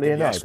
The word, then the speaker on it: Leonidas: Uh, uh, Leonidas is uh, uh, is that right? Is that how, am I pronouncing him right? He we played Leonidas, yes, King of Sparta of Lion Stock.